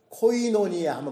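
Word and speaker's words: koinonia: 코이노니아.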